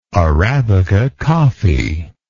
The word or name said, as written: arabica coffee